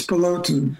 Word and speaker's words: Bolton: It's Bolton.